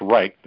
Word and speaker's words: Reich: They spell it R-I-C-H-T in different ways, but it's pronounced Reich.